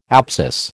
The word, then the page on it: apsis: American English